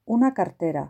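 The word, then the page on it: una cartera: Spanish (Spain)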